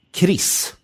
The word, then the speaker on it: KRIS: Så, och du identifierar då framförallt broderskapsrörelsen och KRIS. – Mm. – Uttalas det så? – Ja, det gör det.